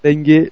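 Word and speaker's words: M'Bengue: Et donc, le hold-up parfait pour Toulouse, hein, qui a fait match nul 1-1 à Lille, grâce à un but de Cheikh Cher Bengue. Voilà, oui, parce que le M se prononce bien. Oui, je galère. Cheikh Mbengue, le grand match.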